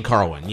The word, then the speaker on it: Carwin: And then it follows with Lesnar versus, let me see how you pronounce it, Carwin. – Shane Carwin, yes. – Carwin, okay, cool.